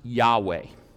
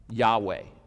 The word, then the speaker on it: Yahweh: The best that we can tell, as God gave his name right here, it is pronounced Yahweh. Anybody ever heard that? Yahweh. Now that's the best we can tell, because in ancient Hebrew, there were no vowel points. There were no vowels.